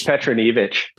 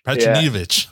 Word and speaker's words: Petronijevic: It's Petronijevic. – Petronijevic.